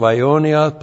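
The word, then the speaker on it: Aionios (αἰώνιος): So while that skenos tent is very, very, very temporary, the resurrection body is aionios, accusative singular of aionios plus the prepositional phrase and plus the dative plural of uranos. Aionios, A-I, long O-N-I-O-S, Aionios, means without end or eternal.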